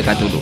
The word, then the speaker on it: Cthulhu: Yo no sé si será en todo el territorio nacional o será solo en Cantabria, pero tenemos una movida que nadie sabe si se dice Cthulhu o se dice Tulu y anda todo el mundo peleándose. – No, bueno, se pronuncia Cthulhu.